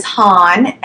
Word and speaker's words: Han: My name is Han, and it's pronounced like Han Solo from Star Wars, and I am from Alexandria, Virginia.